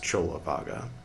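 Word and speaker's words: Cullavagga: This is Buddhist Books Podcast, episode 334, Tipitaka, part 214, and Chulavaga 4, spelled like Kulavaga, pronounced Chulavaga.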